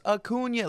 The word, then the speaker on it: Acuña: And then Bryce turns around and he goes, Acuña. It's pronounced Acuña.